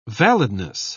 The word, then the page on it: validness: British English